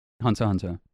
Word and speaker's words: Hunter x Hunter: Hunter x Hunter. – Classic. And he's a true weeb because he said Hunter x Hunter and not Hunter x Hunter because the X is silent.